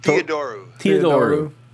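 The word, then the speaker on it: Theodorou: How do you say his last name? Thoradio, right? – Theodoru.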